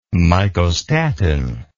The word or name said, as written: mycostatin